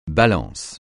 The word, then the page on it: Balance: francais